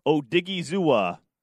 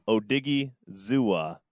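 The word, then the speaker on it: Odighizuwa: So in the fourth round as well, I had Osa Udiga. Oh, man. Give me a moment. I need to pronounce his name correctly in respect to him. Odigizuwa. Osa Odigizuwa, 282,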